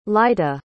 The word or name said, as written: lyta